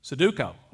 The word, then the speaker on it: Sudoku: She got me hooked on, am I saying it right, Sudoku? How do you say it? Sudoku. Okay.